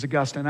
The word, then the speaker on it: Augustine: By the way, the proper pronunciation is Augustine. I don't know who decided that, but that's the case.